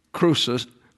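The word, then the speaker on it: krousos (χρυσός): your gold, that's krousos, actually, the impact is on the second syllable.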